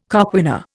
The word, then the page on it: coquina: Indian